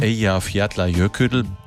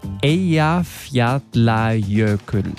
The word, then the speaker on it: Eyjafjallajökull: Schuld war damals, wie würde das unser Bremen 4-Korrespondent Carsten Schmiester aussprechen? – Eja Fjadler Jöködl. – Eja Fjadler Jöködl. – Oh, ich bin stolz auf dich.